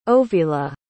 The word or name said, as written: ovila